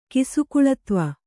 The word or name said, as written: ಕಿಸುಕುಳತ್ವ